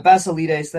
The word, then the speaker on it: Basilides: Yeah, I mean, it's also important to recognize here that this is part of the deal with Basilates here. I'm still not quite sure that I'm pronouncing it correctly. But I said Basilides, but I'm not saying it correct. – Yeah, Basilides, that sounds better.